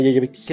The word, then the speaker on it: Jovovich: Oye, Mila Jovovich, sí, o Jovovich, no sé cómo se dice. – Sí, Mila Jovovich.